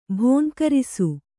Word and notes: ಭುಂಕರಿಸು: bhunkarisu

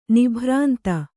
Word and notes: ನಿಭ್ರಾಂತಿ: nibhrānti

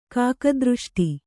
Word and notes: ಕಾಕದೃಷ್ಟಿ: kāka dřṣṭi

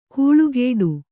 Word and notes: ಕೂಳುಗೇಡು: kūḷugēḍu

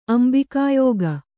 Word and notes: ಅಂಬಿಕಾಯೋಗ: Kannada

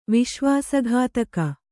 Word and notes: ವಿಶ್ವಾಸಘಾತಕಿ: viśvāsa ghātaki

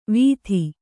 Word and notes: ವೀಥಿ: Kannada

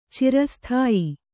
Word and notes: ಚಿರಸ್ಥಾಯಿ: kannada

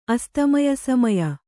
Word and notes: ಅಸ್ತಮಯಸಮಯ: Kannada

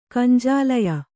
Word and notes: ಗುಂಜಾಲಯ: gunjālaya

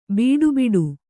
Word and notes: ಬೀಡುಬಿಡು: bīḍu biḍu

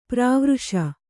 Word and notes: ಪರವರ್ಷ: Kannada

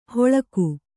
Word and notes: ಹೊಳಕು: Kannada

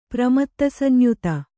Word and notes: ಪ್ರಮತ್ತಸಂಯುತ: Kannada

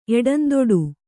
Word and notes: ಎಡಂದೊಡು: kannada

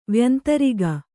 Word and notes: ವ್ಯಂತರಿಗ: Kannada

